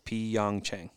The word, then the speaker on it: Pyeongchang: It's three syllables, Pyeongchang.